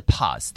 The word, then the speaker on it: POZ: Pose movement，但其实它是 pause，对不对？